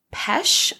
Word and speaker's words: Pesce: So May 7th, 1972, he killed Mary Ann Pesce, I think is how you pronounce it, P-E-S-C-E.